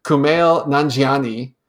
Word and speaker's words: Kumail Nanjiani: I'm I've tried pronoun- repronouncing his name several times, Kumail Nanjiani. Correct. Nailed it.